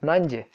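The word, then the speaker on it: Nadi: Таким образом, главный аэропорт, который вот Нанди, ну это Нади пишется Нади, а Фиджи его произносят как Нанди.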